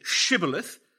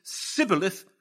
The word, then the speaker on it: Shibboleth: And as for those who cannot say Shibboleth and lay a pretty good stress on the H, but who pronounce it Sibboleth, let the fords of the Jordan be taken and let them be put to death.